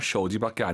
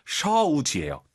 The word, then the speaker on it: 手机: 쇼지 쇼지 아니죠? – 쇼지. – 오유는 어우예요. 굳이 한글로 표기하면 쇼지가 아니라 셔우지예요. 들어볼게요. – 쇼지. – 그렇죠. 쇼지. 쇼우 올라간 순간 틀린 거예요.